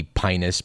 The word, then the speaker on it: Pinus: That's spelled P-I-N-U-S. And you know, I didn't take Latin. It might be Pinus, but I prefer to call it Pinus pinaster, no matter what may be correct.